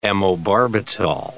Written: amobarbital

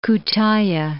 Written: Kutaiah

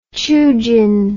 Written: qiujin

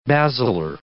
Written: basilar

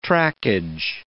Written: trackage

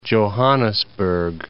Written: Johannesburg

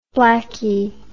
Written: blacky

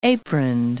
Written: aproned

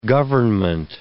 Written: government

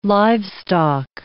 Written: livestock

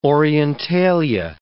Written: Orientalia

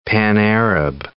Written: pan-Arab

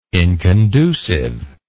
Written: inconducive

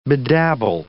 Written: bedabble

